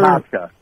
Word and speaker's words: Procházka: The light heavyweight matchup, Jiří Procházka against Alexander. – Prochazka.